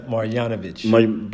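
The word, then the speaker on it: Marjanović: Marjanovic.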